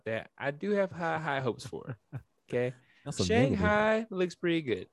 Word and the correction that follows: Shang-Chi: Did I say it wrong? – You butchered the name. – Shang-Chi? Shanghai? – No. – What is it? – No. Shang? – Oh, Jesus. Shang-Chi. Sorry. Shanghai. Ooh, damn, that's way off. Shang-Chi.